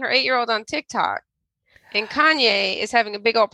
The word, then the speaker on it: Kanye: Are you talking about Kanye? – What did I say? – Kane? – I met Kanye, and he goes by Ye now.